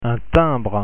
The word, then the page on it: timbre: French